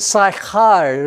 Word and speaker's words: Sychar: But anyway, we pronounce it Sychar. There's that sound again, Bivrit in Hebrew, Char, Sychar. Sychar, near the plot of ground where Yaakov gave his son Joseph.